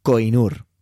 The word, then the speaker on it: Koh-i-Noor: El nombre de este diamante es co i nor lo he pronunciado tal y como se escribe, porque se escribe K-O-H-I-N-O-O-R. Pero creo que se puede pronunciar como Koinur, ¿vale? Voy a decir que se llama Koinur, que significa montaña de luz.